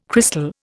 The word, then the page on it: crystal: Indian